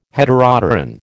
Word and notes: Heteropteran: American English

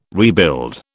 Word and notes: rebuild: British English

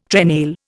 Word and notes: trenail: Indian

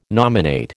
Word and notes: nominate: American English

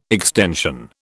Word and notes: extension: British English

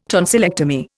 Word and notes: tonsillectomy: Indian

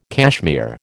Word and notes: cashmere: American English

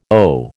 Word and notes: OH: American English

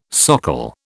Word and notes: socle: British English

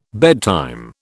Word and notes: bedtime: British English

